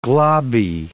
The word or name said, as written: globby